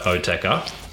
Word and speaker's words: Oetker: So had it not been for Dr. Oetker, I think I'm pronouncing that right. – Yes.